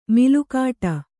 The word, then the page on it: ಮೇಲುಗಡೆ: mēlugaḍe